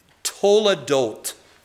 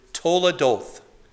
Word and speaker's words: toledot: And if I can just quickly run through the word toledot. If you want to pronounce the TH at the end as hard or soft, that's up to you. But can we all please say the word toledot?